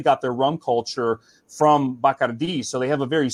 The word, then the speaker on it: Bacardi: And the Bacardi family, we know them as Bacardi, but in Spanish it's called Bacardi.